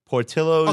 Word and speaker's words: Portillo's: I think it's Portillo's. – Portillo's.